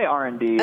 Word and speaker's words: R&D: And do you say R plus D, or am I saying it wrong? – We say R&D. – Okay. – The R plus D is like a signature brand thing.